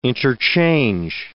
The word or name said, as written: interchange